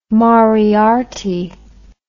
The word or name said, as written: moriarty